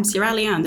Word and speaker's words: Sierra Leone: And you also talked about your trip to Sierra Leone. Mm. Am I saying it correctly? – Yeah, Sierra Leone, that's right.